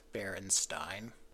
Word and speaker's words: Berenstain: The original author is very insistent that it's actually pronounced Berenstain.